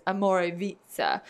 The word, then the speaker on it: Omorvicza: Everyone says it wrong. So it's spelled Omorvicza. And it's Amorevitza. And it is one of the fastest growing luxury green beauty brands.